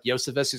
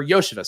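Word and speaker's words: Iosivas: Maybe Tyler Boyd is gone this year and they test out guys like Yosevis, who's got an insane target. Or Yosevis, sorry. Our guy, Purple Yoshi, reminded me of how to pronounce that one.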